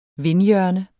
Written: vindhjørne

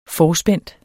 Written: forspændt

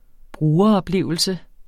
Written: brugeroplevelse

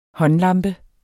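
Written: håndlampe

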